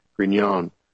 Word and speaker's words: Grignon: And this is Paul's background, and it's spelled G-R-I-G-N-O-N, Grignion.